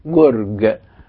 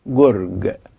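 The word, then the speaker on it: گرگ: القاف بتنطق مثل الجيم المصرية غير المعطشة، غير المعطشة، يعني فيه كلمة فارسية اولها كاف عليها شرطتين وبعدها راء وبعدين الكاف اللي عليها الشرطتين الاثنين دول، يعني اولها زي اخرها، كاف بشرطتين في الاول وكاف بشرطتين في الاخر، فهي تنطق جرج جرج. الاولانية نطقناها هي جو جو، يعني قريبة من الجيم المصرية الغير معطشة.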